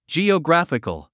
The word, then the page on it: geographical: dʒìːəɡrǽfik ə l; ジィオグラフィカル